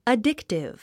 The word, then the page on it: addictive: ədíktiv; アディクティブ